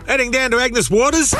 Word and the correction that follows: Agnes Water: Mate, it's pronounced Agnes Water.